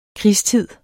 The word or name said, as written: krigstid